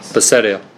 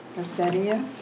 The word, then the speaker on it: Bethsaida: Bethsaida. – Bethsaida.